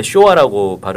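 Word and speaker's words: 쇼화 (昭和): 그게 이제 그 소화라고 이제 하셨는데 일본 말로 이제 쇼화라고 발음이 되는 거고 1989년까지 그걸 썼어요.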